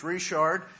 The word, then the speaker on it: Richard: My name is Lucas Richard. My last name is a little bit tough to, to spell. It's spelled R-I-C-H-A-R-D.